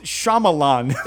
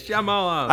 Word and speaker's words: Shyamalan: No, it's Shyamalan. So you say it. It's just a fast name. I'm not afraid to fucking say it. – Shyamalan.